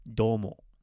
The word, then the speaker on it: どうも: Today's word is another very common word, that is doumo. Doumo is the long U sound at the beginning.